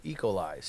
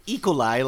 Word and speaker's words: E. coli: No, you're saying it E. coli, and it's E. coli. – Yeah, it's like E. coli. Oh, I'm having an emphasis on the wrong syllable.